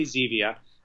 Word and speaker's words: Zevia: Once and for all, the pronunciation of your product is Zevia, correct? – Here's the funny thing. I say Zevia. Jim May, who actually brought the plant stevia to the United States, calls it stevia.